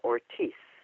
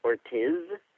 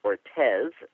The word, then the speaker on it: Ortiz: Hello, my name is Victoria Ortiz. Ortiz is pronounced as if it were spelled O-R-T-E-E-C-E, but it's actually spelled O-R-T-I-Z. It's not pronounced Ortiz. It's not pronounced Ortez,